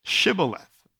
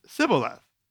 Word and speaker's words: Shibboleth: No, uh, say now uh, Shibboleth. And they would say, Sibboleth. for they could not pronounce it correctly.